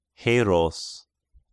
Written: Heros

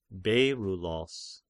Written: Beryllos

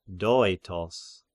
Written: Doetos

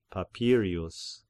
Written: Papirius

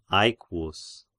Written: Aequus

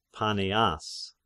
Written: Paneas

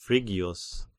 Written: Phrygius

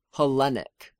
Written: Hellenic

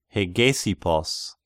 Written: Hegesippos

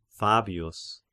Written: Fabius

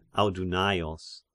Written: Audunaios

